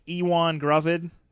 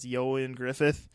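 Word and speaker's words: Ioan Gruffudd: It looks like Ewan Gruvid, but I believe it's pronounced Yon Griffith.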